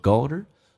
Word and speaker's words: older: Do darker colors make me look older? Look and older 有一个连音，可变成个 golder，同时最难的就是那个 O 的 L 这个发音，首先你发 O 再加个 L， L 是舌尖抬起来靠上你的上齿音叫 O。